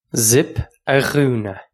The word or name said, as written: zip a dhúnadh